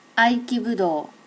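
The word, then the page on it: aikibudo: native Japanese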